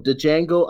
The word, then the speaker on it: Django: So Django Unchained. I keep saying Django because it's just fun to say it. No, it's just fun to say Django. – The D is silent.